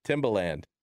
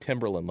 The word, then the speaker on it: Timbaland: So you had like Timbaland in the studio. Am I even pronouncing that correctly? – Yeah, you got it. – Timbaland. I wanted to make sure I wasn't saying Timberland like the boots.